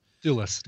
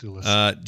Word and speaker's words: Thewlis: Michael Stone, but played by David, however you say his name, Thewlis. – Thewlis, David Thewlis.